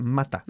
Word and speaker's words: mata: Y mata, por supuesto, se pronuncia mata. – Mata. – Y no mater. Mater. Okay, mata.